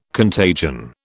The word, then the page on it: contagion: British English